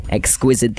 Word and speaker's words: exquisite: When people say D instead of T's. Exquisite. That is just exquisite.